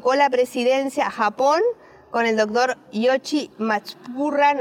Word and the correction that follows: Matsubara: no sé si se pronuncia. Matsubara, no sé la pronunciación, es Yoshi Matsubara, este, de la Universidad Sendai de Japón.